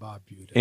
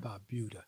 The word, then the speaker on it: Barbuda: Antigua and Barbuda. – Antigua and Barbuda. – Barbuda.